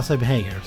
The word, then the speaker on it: Sammy Hagar: Alltså Sammy Hagar? – Ja, precis. Ja, Sammy Hagar. Så säger han.